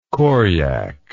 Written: koryak

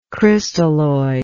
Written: crystalloid

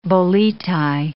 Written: boleti

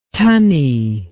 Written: tunney

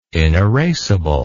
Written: inerasable

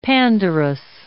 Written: Pandarus

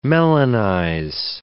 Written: melanize

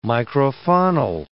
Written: microfaunal

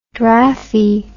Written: draffy

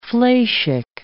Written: fleishig

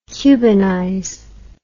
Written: cubanize

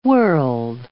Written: world

